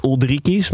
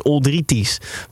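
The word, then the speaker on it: Uldriķis: En ik hoorde iedereen zegt Oldrikis, maar als je het goed wil uitspreken, en Tim, dit is wel een keer van jouw straatje, is het dus Oldritis. Want in let eh, spreek je de K uit als T.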